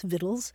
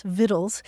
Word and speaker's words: victuals: So today you'll see either the word vittle spelled V-I-T-T-L-E or V-I-T-T-L-E-S, vittles, and you'll also see it spelled V-I-C-T-U-A-L-S, vittles. And you look it up in the dictionary and the pronunciation is vittles for both words.